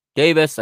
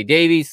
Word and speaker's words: Davies: If you are in the UK, you're probably going to pronounce that Davis. Here in America, we say Davies, so I'm going to say Davies.